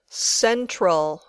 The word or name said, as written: central